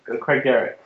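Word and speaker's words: Craigdarroch: The Craig Derrick.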